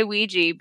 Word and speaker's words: Ouija: Okay, so a lot of people pronounce it Ouija, like it's spelled. Some people, I hear it, sometimes people say Ouija. I say Ouija because I'm from the South and we say things and people don't like it.